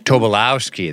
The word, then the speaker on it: Tobolowsky: I don't know how to say his name. Is it Tobolowsky? Tobolowsky? – Tobolowsky, I think. – Tobolowsky. There you go.